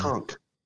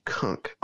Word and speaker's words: Cunk: Kunk. C-U-N-K. Kunk on Earth. – Not K-U-N-K, but C-U-N-K. Often mispronounced cunt. – No, it's no. There's not a T in there. It's a K.